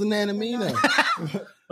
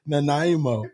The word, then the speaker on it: Nanaimo: He's talking about the pronunciation Nanaimo. – Oh, do it. Do it now. – Nanaimo. – Nanamino. I'm like, what am Nanaimo. I breathing in? – I'm sorry. – Nanaimo. – I forgot. Nanaimo was the Native American woman that helped Lewis and Clark.